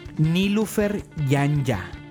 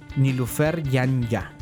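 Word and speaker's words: Nilüfer Yanya: Entonces, la artista que les voy a recomendar se escribe Nilufer Yanya. Nilufer tiene diéresis en la U. Nilufer Yanya. Yanya es Y-A-N-I-Y-A. Yanya.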